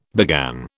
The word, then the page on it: began: British English